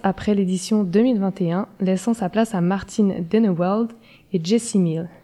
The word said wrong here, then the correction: Denewald: J'espère que je prononce bien. – Euh, c'est euh, Denwald. – Denwald, ok, merci.